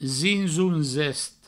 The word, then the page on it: zinzon-zest: zinzoun-zest